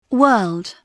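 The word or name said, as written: world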